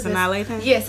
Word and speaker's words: Sanaa Lathan: I don't know how to say her name. – Sanaa Lathan.